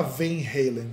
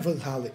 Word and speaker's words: Van Halen: não é Van Halen, é Van Halen. Você fala, bicho, vai ser Van Halen. Porque o. Por aquilo que pareça, o, o holandês tem uma pronúncia muito mais próxima da do português para algumas palavras que, o... que do inglês.